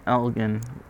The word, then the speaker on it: Elgin: A soft G in Elgin would be very odd to hear. Elgin? That would be like Dijin.